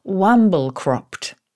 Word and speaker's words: wamblecropt: This is one of his all-time favourite words because he finds it so beautiful to say, wamble-cropped.